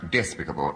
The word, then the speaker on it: despicable: My faults are so despicable, vile, low, that only... – Kenneth Williams. – Deviation. The root is despite, therefore it's pronounced despicable. No, you don't accent the I.